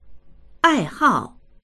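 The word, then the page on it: 爱好: àihào; ai4hao4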